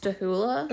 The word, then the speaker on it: Stachula: So it's spelled like Stachula. Uh-huh. But in the episode of Unsolved Mysteries, they repeatedly pronounced his last name as Stahula. – Okay. – And he didn't object, so that's how I'm going to pronounce his last name.